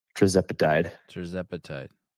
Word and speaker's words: tirzepatide: Tirzepatide.